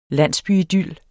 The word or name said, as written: landsbyidyl